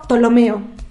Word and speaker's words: Ptolomeo: Pero bueno, una de las personas que me gustaría rescatar de la historia es Claudio Ptolomeo. Recuerden que digo Ptolomeo, pero se escribe Ptolomeo, con una P al inicio, Ptolomeo.